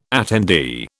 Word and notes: attendee: British English

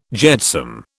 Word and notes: jetsam: British English

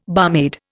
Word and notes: barmaid: Indian

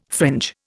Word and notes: fringe: Indian

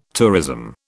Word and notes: tourism: British English

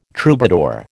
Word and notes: troubadour: American English

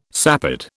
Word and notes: sapid: British English

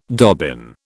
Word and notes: dobbin: British English

